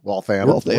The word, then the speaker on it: Waltham: Waltham? Is it Waltham? – Waltham.